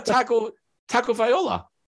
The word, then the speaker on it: Tagovailoa: Tua Tagovailoa. Where did it go wrong? – Well, the O isn't before the L. Tagovailoa.